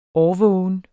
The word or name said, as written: årvågen